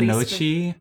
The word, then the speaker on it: gnocchi: The gnocchi. – Yeah, it's spelled with a G, but the G is weird because it's Italian.